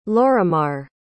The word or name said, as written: lorimar